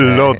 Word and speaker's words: Łódź: Aunque se pronuncia Łódź, se escribe Łódź, por si alguien lo busca Lod, casi no tiene nada que ver la pronunciación.